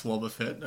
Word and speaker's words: Wobbuffet: It's pronounced Wobbuffet, okay.